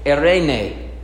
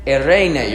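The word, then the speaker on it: Eirene (εἰρήνη): You got to roll your R a little bit. You ready? Eirene. Eirene. You ready? Here we go. Eirene.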